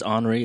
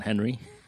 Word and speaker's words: Henri: It's Henri. It's not Henry.